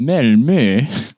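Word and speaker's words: Malmö: I went to Gothenburg and Malmo, which is pronounced Melmö.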